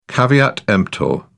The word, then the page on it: caveat emptor: British English